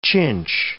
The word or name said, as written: chinch